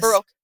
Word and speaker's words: Baroque: I would say I listen to this genre of music a lot, which is, I think it's pronounced Baroque. Baroque? – Mm-hmm. Yeah, yes. Baroque.